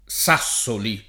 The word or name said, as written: Sassoli